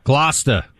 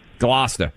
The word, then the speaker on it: Gloucester: How do you pronounce the town? – Gloucester. – Gloucester. – See, Buck knows.